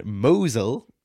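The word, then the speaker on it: Mousehole: So this week they are in Mosel, which is spelt mouse hole, in Cornwall for pastry week.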